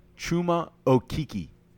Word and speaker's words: Chuma Okeke: Chuma Okeke.